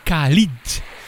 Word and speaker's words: Khalid: Hoor je Ed Sheeran featuring Khalid.